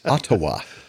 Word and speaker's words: Ottawa: How do you spell Ottawa? It's O-D-O-W-A. – If you want to spell it phonetically, but it's Ottawa.